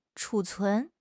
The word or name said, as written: 储存